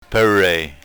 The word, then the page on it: perey: yurok